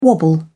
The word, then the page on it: wobble: British English